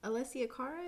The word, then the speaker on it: Alessia Cara: Alessia Cara, is that how you say it? – Yeah.